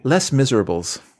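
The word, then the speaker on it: Les Misérables: I am, this is my only Les Miserables, I'm saying it wrong. Les Miserables? – You can just, whatever. – You studied French, you tell me. – Les Miserables.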